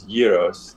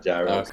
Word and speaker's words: gyros: It's gyros. That's how they say it, not gyros.